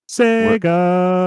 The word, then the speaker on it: Sega: You got to say it right. Sega.